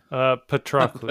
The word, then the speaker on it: Patroclus: refused for it to be buried until Patroclus. Help me out with the pronunciation. – Sounds like it. – Patroclus.